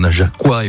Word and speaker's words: Najaquois: Depuis 20h, on s'est pas mal baladé, même si on a offert un joli coup de projecteur aux Najaquois et aux Najaquoises. C'est bien comme ça qu'on prononce. – Tout à fait.